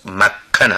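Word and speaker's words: مکن (मक्खन): مکن متوجه شوید، تلفظ مکن است. مکن یعنی کره.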